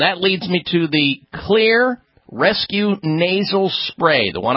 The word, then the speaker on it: Xlear: Clear is spelled X. L-E-A-R. The X stands for xylitol.